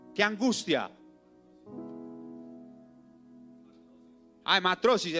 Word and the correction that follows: hematidrosis: hematrosis. Mentiras, Marias. Gracias por desembalarme siempre. Yo lo pronuncié mal.